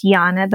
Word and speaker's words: Janne: And I write on Instagram and my name is pronounced Janne, but it's spelled J-A-N-N-E Robinson.